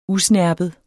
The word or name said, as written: usnerpet